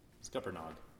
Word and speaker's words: Scuppernong: So, we know the history of North Carolina wine, again, begins with colonization because of Scuppernong and the mother vine. I am pronouncing that correctly, right? – Scuppernog? – Scuppernog. Not Scuppernong. – Scuppernog, yeah. – Okay. – Oops. Hard G at the end.